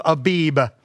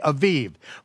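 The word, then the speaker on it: Aviv (אביב): Now, in your King James Version, it'll say the month of Abib. Okay, I don't care whether you pronounce it with the with the, with the vet or the bat.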